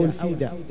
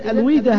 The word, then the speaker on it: الفيدا: فبدأوا في كتابة بعض الأفكار حول هذه المسائل وسميت هذه الأفكار فيما بعد بالويدا أو الفيدا. الفيدا هذا هو كما سنعرف بعد قليل هو أعظم الكتب المقدسة عند هؤلاء، أعظم كتاب مقدس عند الهندوس هو الفيدا. إذن الويدا هذا أو الفيدا ينطق باللغتين، والويدا طبعا كلمة ويد كلمة سنسكريتية، طبعا اللغة السنسكريتية هي اللغة الهندية القديمة ومعناها القانون أو الحكمة، معناها القانون أو الحكمة.